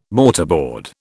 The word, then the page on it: mortarboard: British English